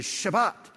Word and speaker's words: Shabbat: It's Sabbath. That's our English pronunciation. But the word in Hebrew is Shabbat.